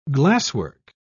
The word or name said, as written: glasswork